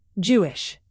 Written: jewish

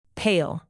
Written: pale

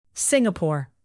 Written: Singapore

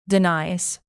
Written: denies

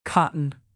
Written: cotton

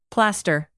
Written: plaster

